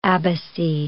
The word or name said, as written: abbacy